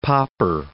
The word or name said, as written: popper